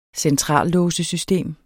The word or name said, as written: centrallåsesystem